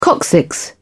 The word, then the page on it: coccyx: British English